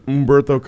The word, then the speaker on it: Humberto: You don't pronounce the H. We've been through this. – Humberto. – Yeah, just Humberto. – Make believe the H isn't there.